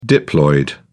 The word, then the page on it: diploid: British English